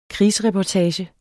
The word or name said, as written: krigsreportage